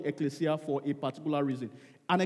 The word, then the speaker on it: Ekklesia: But the original Greek word is E-K-K-L-E-S-I-A, and it's pronounced ecclesia.